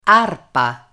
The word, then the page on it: arpa: Italian